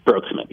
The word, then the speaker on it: Broeksmit: Am I pronouncing his last name right? – Broeksmit, yeah. – Broeksmit.